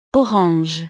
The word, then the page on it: orange: frances